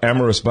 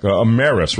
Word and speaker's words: Ameris: So, Gary, let's talk about Ameris Bank. Ameris, rather. I always get that mispronounced.